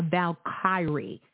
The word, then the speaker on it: Valkyrie: A Valkyrie. Kyrie. That's how you say it. A Valkyrie.